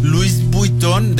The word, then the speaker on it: Louis Vuitton: Louis Vuitton, de buena manera. Louis Vuitton.